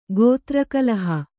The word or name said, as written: ಗೋತ್ರಕಲಹ